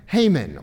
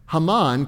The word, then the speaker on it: Haman: We see that intentionality here with just his first name, Haman or Haman. That's probably how it was actually pronounced.